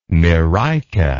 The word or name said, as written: myrica